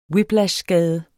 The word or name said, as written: whiplashskade